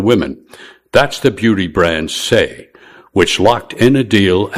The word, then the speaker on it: Saie: How Say, and that is spelled S-A-I-E, became the only Knicks sponsor advertising to women.